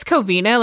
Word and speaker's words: Covina: Covino. I don't know how to say it. It's fine. – I think it's Covina. Like once you said it, I was like, oh, that sounds better.